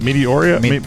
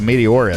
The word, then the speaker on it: Meteora: Number 8, how do you pronounce that? Meteoria? Meteora. Linkin Park. I've never listened to them. Don't want to. Never will. – Oh, I'll tell you, Meteora is mediocre.